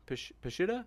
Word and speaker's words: Peshitta: This next one comes from James Snap, who asks, question for Dr. Ehrman, does he think the Peshitta, is it pronounced Peshitta? Peshitta? – Yeah, that's good enough.